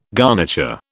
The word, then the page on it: garniture: British English